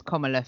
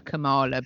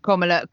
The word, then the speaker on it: Kamala: You see, I've been pronouncing it wrong, so it's Kamala. Fair enough. I've been pronouncing her Kamala, but anyway, Kamala it is.